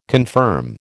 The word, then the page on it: confirm: American English